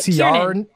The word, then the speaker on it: Ciaran: Ciaran. – Kiernan? – Is it Kiernan? – Get out of here. C-I-A-R-A-N.